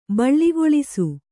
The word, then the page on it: ಬೀಳುಗೊಳಿಸು: bīḷugoḷisu